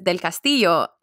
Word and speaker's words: Del Castillo: But the last name is Del Castillo.